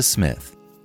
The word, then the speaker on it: Psmith: And now, leave it to Smith. The P is silent.